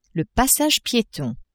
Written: passage pietons

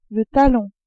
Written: talon

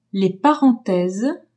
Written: parenthèses

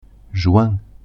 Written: juin

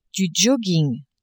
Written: jogging

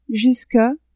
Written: jusque

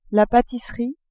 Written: patisserie

